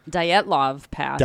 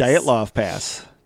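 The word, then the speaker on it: Dyatlov Pass: Season 2, Episode 9, Detlov Pass. – Dietlov Pass. – Dietlov Pass. Yeah, because didn't somebody say I was always saying it wrong? – Well, no.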